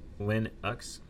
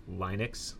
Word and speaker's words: Linux: Linux should be pronounced Linux with a short I, not Linux.